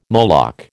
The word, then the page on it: Moloch: American English